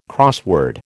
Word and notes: crossword: American English

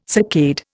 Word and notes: saccade: Indian

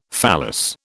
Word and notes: phallus: British English

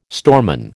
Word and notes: storeman: American English

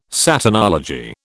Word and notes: Satanology: British English

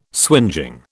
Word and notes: swingeing: British English

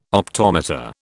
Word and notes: optometer: British English

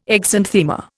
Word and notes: exanthema: Indian

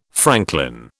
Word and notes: franklin: British English

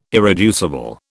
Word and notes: irreducible: British English

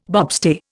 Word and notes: bobstay: Indian

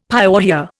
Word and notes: pyorrhoea: Indian

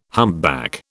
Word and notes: humpback: British English

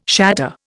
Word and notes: shatter: Indian

